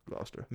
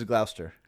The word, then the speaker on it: Gloucester: Everything in Massachusetts is spelled really long but pronounced really short. Like Worcestershire is Worcester. – Worcester? – And this is Gloucester. – Gloucester. – Although but it's spelled like seven consonants.